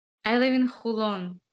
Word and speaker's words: Holon: Holon, it's H-O-L-O-N, Holon.